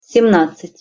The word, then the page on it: semnadcat-: Russian